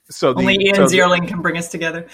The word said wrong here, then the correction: Ziering: Ian Ziering.